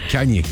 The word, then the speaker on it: Kanye: Comment tu le prononces? – Kanye West. – Kanye. – Ouais, mais vas-y avec un accent.